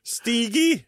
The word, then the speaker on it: Stighi: Stigi. Det är ett H där som man måste uttala. Hi. Stig. – S-T-I-G-H-I. – H-I, ja.